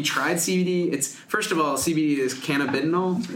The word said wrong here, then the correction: cannabidiol: Is that correct? – I guess. – How do you say it? – This is my pronunciation is, now I'm going to say it wrong. cannabidiol. – Cannabidiol. Okay. – Yes. This is the Canadian pronunciation that you hear at the medical conferences.